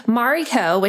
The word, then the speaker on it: Marri: Mari, my nickname, is spelled M A R R I because my parents thought that if they stuck with M A R I, people would say Mary or Marie.